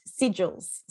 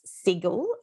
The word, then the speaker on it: sigil: So this week we're looking at sigils. So I think it can be pronounced sigil as well. Okay. But I'm just going to say sigil.